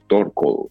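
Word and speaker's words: Turkle: Hay un libro muy interesante, ella se apellida Torco, se escribe, por si alguien quiere buscar esa información, Turcle, así se escribe para decirlo como castellanizado. Okay. Y ese cle es con K, no con C.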